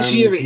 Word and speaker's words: Giry: Madame Giry.